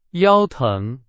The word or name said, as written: 腰